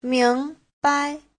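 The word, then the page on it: 明白: ming2bai5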